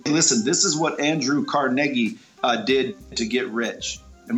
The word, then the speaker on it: Carnegie: Okay, Andrew Carnegie, one of the richest men to ever live. It's Carnegie. That's how you pronounce his name.